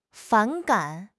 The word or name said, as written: 反感